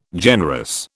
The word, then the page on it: generous: British English